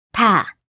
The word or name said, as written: 다